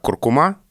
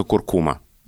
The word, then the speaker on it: куркума: Вот смотрите, есть такое слово, которое почти все носители языка произносят как «куркума», а словари настаивают, что правильно только «куркума».